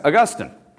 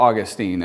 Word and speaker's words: Augustine: Yeah, Augustine, or Augustine, as us Western Americans say it.